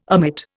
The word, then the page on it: omit: Indian